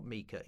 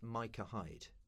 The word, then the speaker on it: Micah Hyde: that's pronounced Micah Hyde." It's not Mika, it's Micah Hyde.